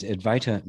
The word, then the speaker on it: Advaita: which is a Sanskrit word, which means advaita means not to, not divided, right? Divida. Advaita. It's not easy thing for the English mouth to say, to pronounce it correctly.